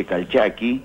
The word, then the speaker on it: Calchaquí: Y ese, esa palabra no se acentúa, porque no acostumbraban a acentuar las palabras agudas y era un lenguaje de tipo guteral. Por lo tanto, la forma correcta de pronunciarlo sería el Valle Calchaqui, este, los Calchaquis, ah, este, o el Valle Calchaqui.